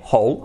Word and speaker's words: whole: Lo mismo, uno se escribe H-O-L-E, hole, agujero, y el otro sería completo, sería lo mismo pero con una W antes, W-H-O-L-E, hole. But the sound is exactly the same, hole. For example, wow, there's a hole on the floor.